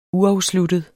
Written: uafsluttet

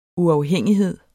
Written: uafhængighed

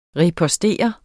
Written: ripostere

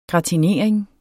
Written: gratinering

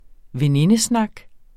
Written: venindesnak

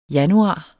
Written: januar

